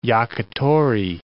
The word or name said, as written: yakitori